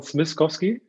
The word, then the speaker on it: Smieszkowski: Name Marcel, du hast richtig ausgesprochen, Smiskowski. Mhm.